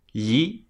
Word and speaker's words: gli: And that's spelt G-L-I, pronounced like an L, but the tongue is flat and it glides across the roof of your mouth.